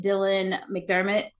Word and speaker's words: Dylan McDermott: We get Dylan McDermott.